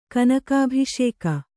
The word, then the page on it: ಕನಕಾಭಿಷೇಕ: kanakābhiṣēka